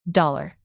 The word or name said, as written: dollar